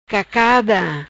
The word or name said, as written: Cacada